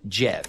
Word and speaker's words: GIF: However, back in 2013, Will Hite told the New York Times that he pronounces it with a soft G, making it GIF.